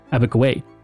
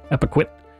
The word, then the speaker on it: Epiquit: Abiquiué, a European mispronunciation of the Mi'kmaq word Epiquit, which means land cradled on the waters.